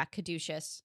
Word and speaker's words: Caduceus: Caduceus, the Caduceus staff, is that how you pronounce it? – Yeah, Caduceus.